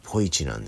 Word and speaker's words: ポイチ: ニックネームポイチなんですよ。 Yes sounds like po and はじめ means 一、要は漢字の読み方をちょっと変えてるだけなんですよね。